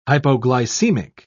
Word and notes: hypoglycemic: British English

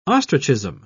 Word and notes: ostrichism: British English

